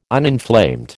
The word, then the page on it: uninflamed: American English